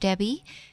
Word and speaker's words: Debby: You can always text in a question to my Facebook page, which is Coach Debbie, and it's spelled D-E-B-B-Y.